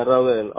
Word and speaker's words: Raudel: Yeah, Raudel, R-A-U-D-E-L.